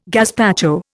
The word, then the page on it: gazpacho: Indian